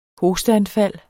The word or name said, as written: hosteanfald